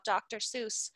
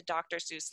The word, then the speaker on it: Seuss: Fun fact about Dr. Seuss, his name is actually pronounced Dr. Seuss, but when he became such a big deal and such a famous author and writer, his name was said wrong all the time.